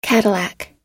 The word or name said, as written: cadillac